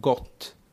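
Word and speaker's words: GOOH: Och han sa att det skulle uttalas som gott.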